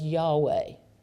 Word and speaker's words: Yahweh: And so that's His personal name, and then somewhere in the history of all of it, it came to be pronounced as Yahweh, YAHWEH.